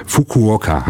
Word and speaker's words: Fukuoka: Und zwar in die japanische Stadt Fukuoka.